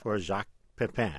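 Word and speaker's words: Jacques Pépin: For Jacques Pepin.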